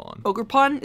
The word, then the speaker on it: Ogrepon: Ogrepon, is that what it is? – Yep.